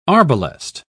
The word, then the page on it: arbalest: British English